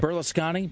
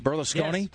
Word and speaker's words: Berlusconi: Oh yeah, Berlusconi. – Berlusconi.